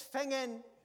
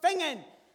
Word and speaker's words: Finzean: F-I-N-Z-E-A-N, Fingen! – Okay, Mr. Dugewid, so that's 12 Garriott Road, Finzine. – No, it's 12 Geary Road, Fingen!